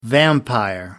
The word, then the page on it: vampire: American English